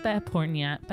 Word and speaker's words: Behpoornia: We've gathered here today to celebrate the life of Kimia Behpoornia.